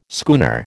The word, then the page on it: schooner: American English